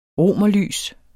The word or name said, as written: romerlys